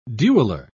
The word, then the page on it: dueller: British English